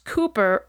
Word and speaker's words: Cowper: His name is spelled C-O-W-P-E-R and pronounced Cooper rather than, as I thought when I started writing this episode, Cowper.